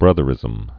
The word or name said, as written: Big Brotherism